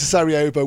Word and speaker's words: Sarajevo: Go on, pronounce that one differently. – No, I think you got that right. – We're going to Sarajevo, which of course is the home of the famous Sarajevo Haggadah, but much more besides.